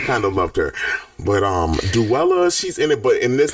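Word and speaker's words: Duela: They have the people in it are Dula. I'm sorry, did you know that Dula Dent's name is Duella Dent? Her name is pronounced Duella.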